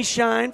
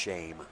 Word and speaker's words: Shine: And it's not shine. It's pronounced shame. – It's shame. Okay.